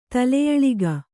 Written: ತಲೆಯಳಿಗ

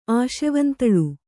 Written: ಆಶೆವಂತಳು

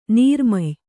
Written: ನೀರ್ಮೆಯ್

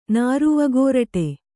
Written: ನಾರುವಗೋರಟೆ

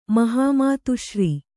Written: ಮಹಾಮಾತುಶ್ರೀ

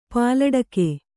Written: ಪಾಲಡಕೆ

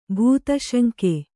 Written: ಭೂತಶಂಕೆ

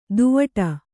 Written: ದೂವ್ವಾಡ

